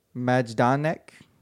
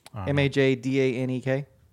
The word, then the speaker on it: Majdanek: Majdanek. Majdanek.